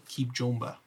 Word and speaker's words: Kibjomba: Keep what now? – Keep Jomba. – How do you spell that? – K-I-B-J-O-M-B-A. J-O-M-B-A. J-O-M-B-A. – K-I-B-J-O-M-B-A. – Got it.